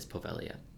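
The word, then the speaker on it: Poveglia: They do pronounce the island name wrong, but just know it is Povelia.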